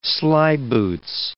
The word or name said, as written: slyboots